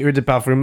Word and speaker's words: Eau de Parfum: I say Eau de Parfum like that because that's how they say it in the commercial.